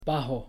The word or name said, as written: bajo